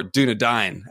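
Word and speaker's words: Dúnedain: House Dayne, they should be House Dunedain or Dunedain as it's pronounced, because that's who they are.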